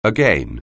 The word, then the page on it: again: anglais